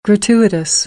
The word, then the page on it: gratuitous: American English; t - ʧ